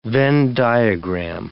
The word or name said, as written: Venn diagram